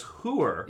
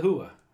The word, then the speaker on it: whore: North Jersey listeners, I guess there's a sect of people that pronounce whore as "hooer." – Yeah, she's a hooer.